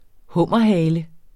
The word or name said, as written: hummerhale